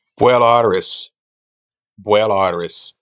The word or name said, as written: beul-aithris